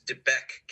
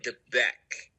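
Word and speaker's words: De Beque: help me with the pronunciation, Debak? – I believe this is Debek Canyon. – Debek.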